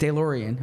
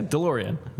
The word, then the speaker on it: DeLorean: So you go into that DeLorean. Am I pronouncing it correctly? – No. – DeLorean? – Yeah, DeLorean.